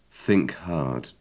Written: think hard

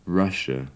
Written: russia

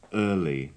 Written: early

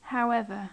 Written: however

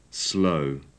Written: slow

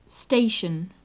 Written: station

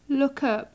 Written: lookup

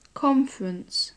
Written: conference

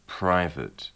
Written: private

